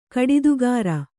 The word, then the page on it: ಕಡಿದುಗಾರ: kaḍidugāra